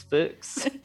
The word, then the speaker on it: Fuchs: Rhymes with books. Fuchs.